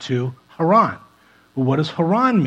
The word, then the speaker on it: Haran: It's actually pronounced Haran, something like that, but uh, we'll just stick with Haran.